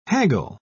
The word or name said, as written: haggle